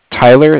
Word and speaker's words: Tyler: My name is Tyler. It's spelled T-Y-L-E-R. – Mm-hmm. – And I'm calling from Cardiff. It's spelled C-A-R-D-I-F-F, as in Frank Frank.